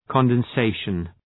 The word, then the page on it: condensation: ,kɒnden’seıʃən